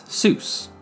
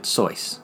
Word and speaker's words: Seuss: while he used the traditional German pronunciation of Seuss. Most other people in Dartmouth read it as Seuss, rhyming with goose.